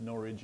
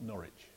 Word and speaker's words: Norwich: I have nothing to say about the introduction except thank you very much, and we prefer that the city of Norwich is pronounced Norwich and not Norwich, just so that you know. What I always say is no itch in Norwich. It rhymes with porridge.